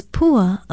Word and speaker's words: pua: Our Hawaiian word of the day is pua, a very well-known and often used Hawaiian word. It is spelled P-U-A and in most common usage means flower, blossom, even the tassel and stem of sugarcane.